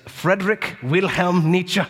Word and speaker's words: Friedrich Wilhelm Nietzsche: A philosopher that I continue to love, despite the fact that we come to the exact opposite conclusions, is Friedrich Wilhelm Nietzsche. You've got to say his name like that.